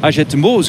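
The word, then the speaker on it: Hagetmau: Na de start er in Agetmeau. Zo moet je het precies uitspreken.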